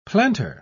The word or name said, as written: plantar